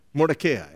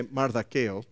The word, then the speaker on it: Mordecai: Mordecai. I was going to say Mardakeo, but it's Mordecai in English.